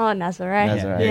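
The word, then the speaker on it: Nazaré: It was at Nazare. Or Nazir. How, I don't know. How do you pronounce it? – Oh, Nazare. Yeah.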